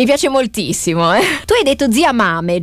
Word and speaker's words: Mame: Io l'ho sentito Corretto. – Pronunciare in 20 modi diversi, Zia Mame, Zia Mame, però noi lo diciamo Zia Mame, così è anche un po' più facile da andare a ritrovare se per caso qualcuno se lo volesse segnare. È proprio scritto così.